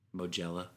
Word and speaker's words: Mugello: I think it's pronounced Mugello, which are both races in Italy.